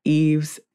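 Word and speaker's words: Yves: Yes, you can find me online on the internets, on Instagram at Not Apologizing, and on Twitter at Eves Jeff Coach.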